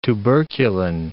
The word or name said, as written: tuberculin